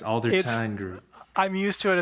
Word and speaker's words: Aldertine: You know, it, it's actually Aldertine.